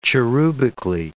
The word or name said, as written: cherubically